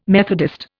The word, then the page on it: Methodist: Indian